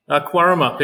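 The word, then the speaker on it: Cowaramup: Quarrumup. If you imagine it has a Q at the start, it makes it easier. – There we go, Quarrumup.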